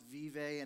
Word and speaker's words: Vibe: It's actually pronounced vive, and it's for those of you who speak Spanish, it means life.